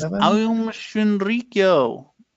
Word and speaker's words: Aum Shinrikyo: Well, episode 7 is Aum Shinrikyo.